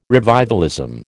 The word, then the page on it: revivalism: American English